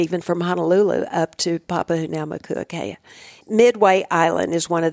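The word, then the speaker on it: Papahānaumokuākea: Papahoutnaumokuakea. – Yeah, that's exactly it. – Papahoutnaumokuakea is a national marine monument that President Bush designated in the northwestern Hawaiian Islands.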